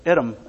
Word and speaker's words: Edom: Not Edom, but Edom. Or we say Edom because of the long E. But it's many times mispronounced.